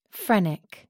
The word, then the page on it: phrenic: American English